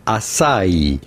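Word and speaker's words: açaí: Not acai, but rather from Brazilian acai.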